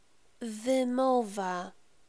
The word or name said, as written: wymowa